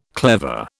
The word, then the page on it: clever: British English